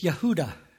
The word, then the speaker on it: Yehuda: It's Yehuda is the proper way it's pronounced in Hebrew.